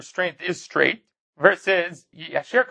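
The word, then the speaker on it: Yasher (יישר): which would mean that the proper pronunciation is not Yashar, but rather Yasher.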